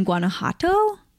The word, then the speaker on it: Guanajuato: Go back to her family life and Olivia's grandparents who were born in Guanajuato. Do you know how to pronounce that? – That sounds... – Guanajuato? – That sounds good.